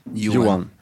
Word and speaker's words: Johan: Johan.